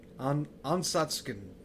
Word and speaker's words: Ansatsuken: I figured out Ken and Ryu's fighting style. It's Ansutsuken. Ansatsuken. Yeah, it's hard to pronounce. Okay. – Yes. There you go. – You said it was a mouthful. – Yeah. – I guess it is. – It's and like, more phonetically, it's Ansatsuken.